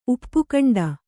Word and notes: ಉಪಖಂಡ: upa khaṇḍa

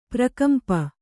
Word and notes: ಪ್ರಕಂಪ: prakampa

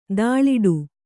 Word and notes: ಧೂಳಾಟ: kannada

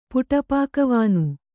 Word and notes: ಪುಟಪಾಕವಾನು: Kannada

